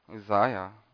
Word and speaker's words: Uzziah: Now, just to give you a little bit of background, how do you say that king's name? Uzziah. Is it like that? Just like that.